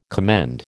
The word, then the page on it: commend: American English